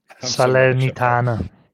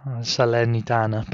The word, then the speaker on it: Salernitana: So the links currently are Leicester, Southampton, Everton, Leeds, Forest, and then two Italian clubs correct me if I say this wrongly. You're the Italian expert here. Um, Saler... Salernitana. – yeah, that's it.